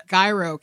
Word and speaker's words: Gyro: The most alarming part was when Max gets into an accident and is picked up by the Gyro Captain. Did I say that right? – Yeah. – Gyro, it's not Euro, certainly.